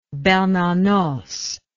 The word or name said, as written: Bernanos